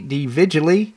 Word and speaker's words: DeVigili: Right now, we have with us Christy DeVigili. I hope I'm pronouncing that correctly. She's the Program Director of Alternative Solutions at the Florida Citizens Alliance. Christy, thank you so much for joining us. – Thank you so much for having me, and you did a great job pronouncing my last name.